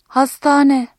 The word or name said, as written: hastane